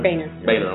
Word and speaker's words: Boehner: Is it Boehner? – Boehner, I'm sorry, I pronounced it wrong.